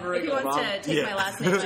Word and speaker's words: Riggle: Riggle? – Yeah.